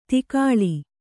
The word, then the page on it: ತಗ್ಗಾಳು: Kannada